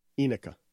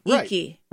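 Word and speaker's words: Ineke: No, it's I-N-E-K-E. Iniki. – Right. – Iniki. – Iniki? – Right. – Yeah. – That's what it looks like, not Enika. – That's because you're trying to pronounce every letter. – Okay. Well, maybe I-N-E-K.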